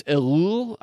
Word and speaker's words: Ellul: That it's, I think his name is Elul. I can't ever say his name right. It's like E-L-L-U-L.